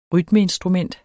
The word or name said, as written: rytmeinstrument